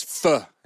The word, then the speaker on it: pho: How do you spell pho? – Pho. P-H-O.